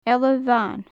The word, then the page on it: eala bhan: gaelic